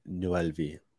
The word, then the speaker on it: Noelvi: Noelvi. – Noelvi.